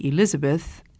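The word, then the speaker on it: Elisabeth: My parents wanted to give all of us children Bible names, and they named me Elizabeth after the Elizabeth in the King James Bible, the cousin of the Virgin Mary.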